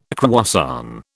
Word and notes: croissant: British English

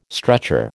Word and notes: stretcher: American English

